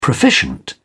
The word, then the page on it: proficient: British English